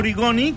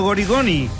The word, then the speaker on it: Origoni: Origoni que no Gorigoni. O sigui, que quedi clar, eh?